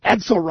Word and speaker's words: Edsel: Edsel rhymes with pretzel.